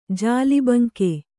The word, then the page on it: ಜಾಲಬಂಕೆ: kannada